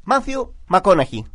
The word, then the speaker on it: Matthew McConaughey: Matthew McConaughey. ¿Se pronuncia así, ¿no? – Sí, perfecto.